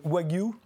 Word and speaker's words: Wagyu: Et, alors, vous avez fait une recette à partir du bœuf euh, Wagyu, on le prononce comme ça, vous l'appelez appelé le bœuf Wagyu Gunma, on va le voir ici.